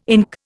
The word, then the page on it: ink: Indian